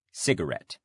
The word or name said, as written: cigarette